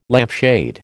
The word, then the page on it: lampshade: American English